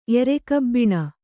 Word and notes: ಎರೆಕಬ್ಬಿಣ: erekabbiṇa

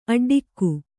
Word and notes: ಅಡ್ಡಿಗೆ: kannada

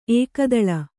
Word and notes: ಏಕತಾಳ: ēkatāḷa

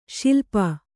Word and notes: ಶೈಲಪ: śailapa

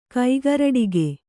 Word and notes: ಕೈಗರಡಿಗೆ: kannada

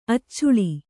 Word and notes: ಅಚ್ಚುಳಿ: accuḷi

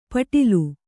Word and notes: ಪೆಟಲ: kannada

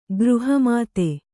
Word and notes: ಗೃಹಮೇಧಿ: gřha mēdhi

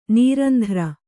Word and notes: ನಿರಂತರ: nirantara

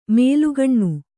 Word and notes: ಮೇಲುಗಣ್ಣು: mēlugaṇṇu

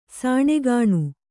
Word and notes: ಸಣ್ಣಗಣ್ಣ: saṇṇagaṇṇa

